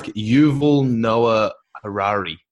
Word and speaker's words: Yuval Noah Harari: It's like Yuval Noah Harari.